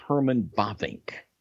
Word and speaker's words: Bavinck: His name is Herman Bavinck. And Herman Bavinck. – How do you spell that? – B-A-V-I-N-C-K.